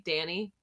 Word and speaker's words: Dani: My name on Instagram is Decayed Danny, and it's spelled exactly, you know, just D-A-N-I.